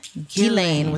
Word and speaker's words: Ghislaine: So it's French. It is Ghislaine. Ghislaine with a hard G. I think if you were really trying to speak it in French, it would be Ghislaine, but I think just saying Ghislaine for our purposes is fine.